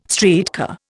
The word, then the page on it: streetcar: Indian